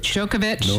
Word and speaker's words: Djokovic: Djokovic.